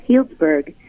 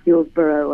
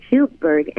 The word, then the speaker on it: Healdsburg: My hometown was a town called Healdsburg, which is spelled H-E-A, but it's not Healdsboro or Hill. It's Healdsburg,